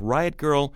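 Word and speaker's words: Grrrl: Riot Girl, girl spelled with three R's, no I, girl.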